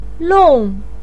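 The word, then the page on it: long4: pinyin; tone 4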